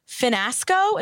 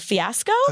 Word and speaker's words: fiasco: That Monday night's episode, the big drama was the girl said, Hannah Ann said, Finasco instead of fiasco.